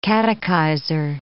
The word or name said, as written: catechizer